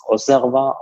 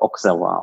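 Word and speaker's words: Auxerrois: Der Wein, meines Wissens nach, wird er als Osserwa ausgesprochen, also X wie S. Man kann ihn aber auch Auxerwa aussprechen.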